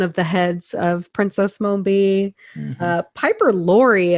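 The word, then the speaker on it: Mombi: And also she is the original Princess Mumby. Mumby? Am I saying that right? – I think so. Yeah. – Mumby. – Mm-hmm, Mumby.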